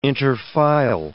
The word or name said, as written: interfile